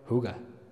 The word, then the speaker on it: hygge: Se escribe una palabra danesa. Se escribe H-Y-G-G-E. Se pronuncia, según me dijeron, Huga.